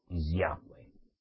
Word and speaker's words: Yahweh: Por tanto, yo les aconsejo a todos los que lean las escrituras del hebreo al español, que donde vean YHWH, pronuncien Yahweh. Yahweh.